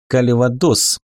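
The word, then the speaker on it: Кальвадос: «У нас нет буквы, обозначающей смягчение согласной, а так-то было бы правильно произносить «калвадос» через «л», а не «л».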